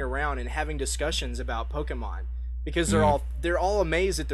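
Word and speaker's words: Pokémon: It has more to do with, like, little kids running up to their parents and saying, let me show you my Pokemans.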